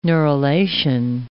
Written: neurulation

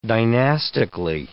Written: dynastically

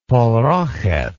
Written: parokheth

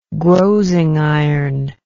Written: grozing iron

